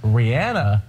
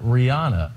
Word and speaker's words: Rihanna: She wants everyone to know it's Rihanna, not Rihanna.